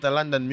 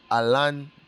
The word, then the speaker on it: Alandon: You know, you can always find me at London Music. My name is spelled A-L-A-N-D-O-N. That is A-L-A-N-D-O-N. One word. London, not A-L-O.